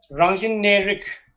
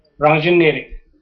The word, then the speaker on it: Rangjin Nerik: So here now, Rangjin Nerik, that it's composed of three syllables, Rangjin Nerik.